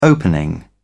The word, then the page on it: opening: British English